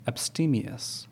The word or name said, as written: Abstemious